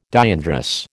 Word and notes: diandrous: American English

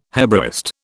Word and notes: Hebraist: British English

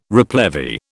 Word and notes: replevy: British English

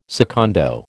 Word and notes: secondo: American English

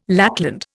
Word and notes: lackland: Indian

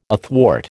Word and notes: athwart: American English

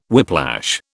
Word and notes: whiplash: British English

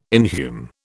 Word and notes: inhume: British English

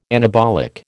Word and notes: anabolic: American English

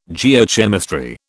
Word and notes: Geochemistry: British English

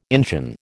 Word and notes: Injun: American English

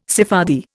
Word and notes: Sephardi: Indian

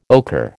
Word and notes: ochre: American English

